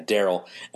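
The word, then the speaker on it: Darel: It's spelled in a cooler way. It's D-A-R-E-L. Yeah, Daryl.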